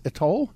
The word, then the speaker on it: Etoile: And Sarah Fuller and Chris Dempsey from, I don't know how to pronounce this, E-T-O-I-L-E, Texas, Etoil? – Etoil. – Etoil, yeah. Okay, there you go. Etoil, Texas. You learn something new. – Yeah, or as the French would call it, Etoile.